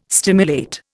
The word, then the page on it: stimulate: Indian